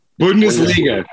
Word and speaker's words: Bundesliga: Bundesliga.